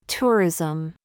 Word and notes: ZOOS: American English

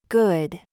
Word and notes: gig: American English

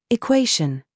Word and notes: GENRE: British English

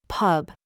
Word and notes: BOB: American English

